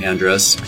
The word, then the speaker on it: Andres: And it's spelled San, A-N-D-R-E-S. There's no A at the end.